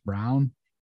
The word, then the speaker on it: Braun: Uh, it's spelled like Braun, but you said it's pronounced Brown.